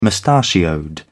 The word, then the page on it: mustachioed: British English